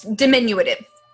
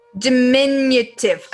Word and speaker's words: diminutive: It is diminutive. – Whoa, that's small. – Diminutive. I said that wrong. Diminutive.